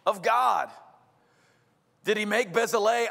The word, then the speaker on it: Bezalel: Now listen, if I was doing my Georgia accent reading this name, I would call this name Bezalel. But I looked it up, and that's not how you say it. It's called Bezalel, which doesn't make any sense to me at all, but that's Hebrew.